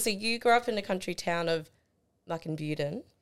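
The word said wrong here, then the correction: Muckinbudin: Good try. – Oh, close. – No. – Muckinbuden. – I even phonetically wrote it out too. I was so close. Okay, no. I'm glad you corrected me.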